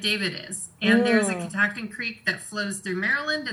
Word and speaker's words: Catoctin: It's always Catoctin. Catoctin. If you put the accent on the second syllable, you will say it right.